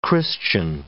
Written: Christian

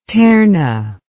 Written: terna

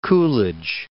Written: Coolidge

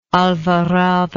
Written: alvarado

